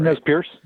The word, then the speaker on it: Nez Perce: The Nez Perce, is it Perce Trail? Nez Perce? Did I say that right? – Nez Perce.